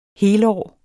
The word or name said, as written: helårs